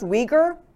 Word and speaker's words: Uyghur: It starts with a U, but it's pronounced Uyghur.